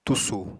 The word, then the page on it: tusù: apatani